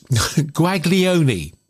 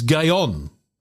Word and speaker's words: Guaglione: if you're looking at how it's spelt, it's Guaglione, but it isn't. It's Gayon. Yeah, try and work that one out.